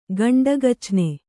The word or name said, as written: ಗಂಡಗಚ್ನೆ